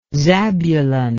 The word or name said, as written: zabulon